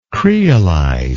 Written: creolized

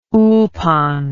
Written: ulpan

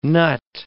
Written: nut

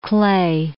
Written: Klee

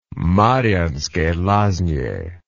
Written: Marianske Lazne

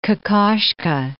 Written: Kokoschka

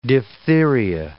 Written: diphtheria

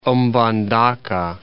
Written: Mbandaka

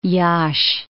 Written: Iasi

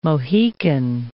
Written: Mohican

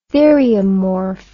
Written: theriomorph

